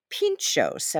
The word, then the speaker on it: pintxos: Pintxos, am I pronouncing that right? – Absolutely. – Pintxos, right, yeah.